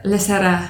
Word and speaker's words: Lysaria: And so new project is Lysaria, which I've heard you pronounce in the... – Lysaria. – Right, but there's a... – Lysaria. – Yes, the Italian way.